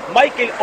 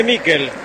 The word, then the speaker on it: Miquel: ¿Michael o Miquel? Nicolás Álvarez, usted que es especialista en nombre, se escribe Miquel. ¿Cómo le digo? – Decile Miquel.